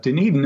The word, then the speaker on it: Dunedin: It's a Scottish name, so it's not pronounced at all like it looks. But Dunedin is located on the Gulf Coast of Florida, about halfway up, just about 45 minutes northwest of Tampa Bay.